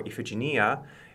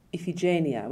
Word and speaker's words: Iphigenia: I keep saying Iphigenia, which I think is probably the Italian. – Oh, I think so. Yeah, Iphigenia.